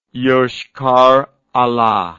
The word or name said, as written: Yoshkar-Ola